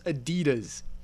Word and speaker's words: Adidas: If it was like a purely British word, I'd give you some leeway here, but the company calls themselves Adidas. That's how it's pronounced.